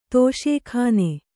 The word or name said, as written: ದೋಷಘ್ನ